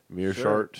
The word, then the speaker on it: Meerschaert: How fuck Gerard Meerschaert. – Yeah. – Is that how you say it? – Sure. – Meerschaert. Sure.